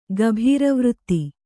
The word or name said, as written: ಗಭೀರವೃತ್ತಿ